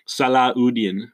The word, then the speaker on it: Salahuddin: the most powerful groups in Latin Europe go against the Salahuddin, which is pronunciation, the original pronunciation.